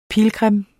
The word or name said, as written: pilgrim